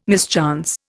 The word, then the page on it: mischance: Indian